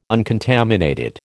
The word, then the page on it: uncontaminated: American English